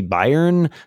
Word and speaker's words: Bayern: I can finally announce that my next book will be The Bayern Agenda, although I'm told in German it's actually Bayern.